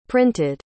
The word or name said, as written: printed